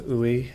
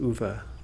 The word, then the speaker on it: Uwe: Last time I called him Uwe, but it's pronounced Uwe, apparently, because we heard a song last week too.